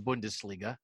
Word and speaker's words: Bundesliga: And it's nice what happens in the Bundesliga. Did I say that right? Is it Bunda? – Yes, you did.